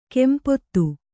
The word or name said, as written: ಕಂಪಿತ